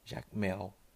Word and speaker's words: Jacmel: Jacmel seria em português a pronunciação certa.